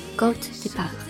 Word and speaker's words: Côte des Bar: 听完巴尔山坡的介绍，它的法语怎么念呢？Goat de Bar，Goat de Bar。 Goat de Bar，你学会了吗？